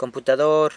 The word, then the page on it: computador: San Lucas Quiaviní